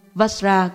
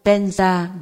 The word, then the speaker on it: Vajra: Câu thần chú tôi khuyên các học trò tôi đọc là Om A Hum Vajra Guru Padma Siddhi Hum. Người Tây Tạng nói Om A Hum Penza Guru Padma Siddhi Hum là thần chú của Padmasambhava, của tất cả chư Phật, các bậc thầy và những bậc đã chứng ngộ.